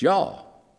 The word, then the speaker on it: y'all: It's not two syllables. It's y'all.